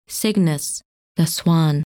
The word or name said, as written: Cygnus